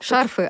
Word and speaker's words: шарфы: Так, Шарфы, так. оказывается, ударение все-таки на буковку А. Я молодец. Да, шарфы, как и банты, и многие другие слова второго склонения.